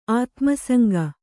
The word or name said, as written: ಆತ್ಮಸಂಗ